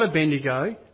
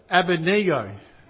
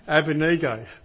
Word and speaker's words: Abednego: not Abednego, Abednego. We've got to get the name right. Colleen and I have a bit of a wrestle with that. Abednego.